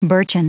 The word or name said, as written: birchen